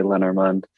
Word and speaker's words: Lenormand: I say Lenormand. Okay. Lenormand. Some people say Lenormand.